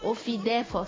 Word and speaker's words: OFIDEF: Non, Ofidef, ça s'écrit O-F-I-D-E-F.